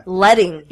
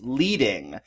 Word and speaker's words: leading: Letting. – It is the letting. And this one is a good one because there's two bits in here. One is that a lot of people think it's pronounced leading.